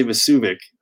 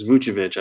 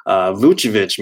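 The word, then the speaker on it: Vučević: Is it Vucevic? I always want to say Vucevic, but it's Vucevic, I believe.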